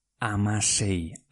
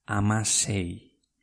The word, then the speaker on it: hamasei: Pues escribe Amasei. H-A-M-A-S-E-I. Amasei.